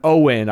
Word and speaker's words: Oin: And yes, youngest son, Owen, I believe it's pronounced. – Owen, yes. – They say, even though it's spelled with a bunch of extra vowels. – O-I-N. – Yeah, Owen.